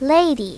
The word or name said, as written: lady